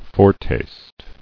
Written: Foretaste

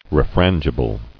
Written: Refrangible

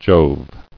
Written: Jove

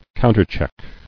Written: Counterchecked

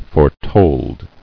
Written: Foretold

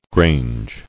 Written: Grange

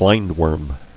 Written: Blindworm

